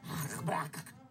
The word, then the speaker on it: Habakkuk: Actually, it's, it's Hebrew, so it'd be Habakkuk.